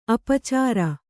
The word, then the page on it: ಅಪಚಾರ: apacāra